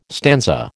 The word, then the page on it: stanza: American English